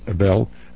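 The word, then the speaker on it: Ebell: Our guest is Myron Ebel.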